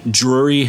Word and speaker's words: Drury: And we're on the 19th floor of the Drury Hotel.